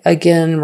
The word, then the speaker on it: again: Again, we say again, again rhymes with win, sin, been, again. Even though it's spelled again, we say it again.